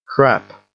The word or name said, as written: crap